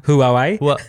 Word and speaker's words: Huawei: Jag, jag säger Hawaii för jag kan inte uttala Hawaii. – Huawei. Huawei, ja.